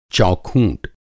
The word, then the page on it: चौखूँट: Hindi